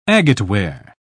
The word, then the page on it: agateware: British English